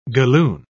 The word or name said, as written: galloon